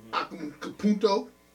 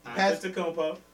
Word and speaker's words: Antetokounmpo: For the East, we got, hold on, let me bring it up, Joel Embiid, Giannis, Antetokounmpo. – Nope. Antetokounmpo.